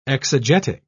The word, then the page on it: exegetic: British English